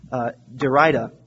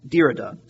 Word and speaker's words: Derrida: particularly a man by the name of Jacques Derrida. Actually, that's not right. Derrida is how you pronounce it, Derrida.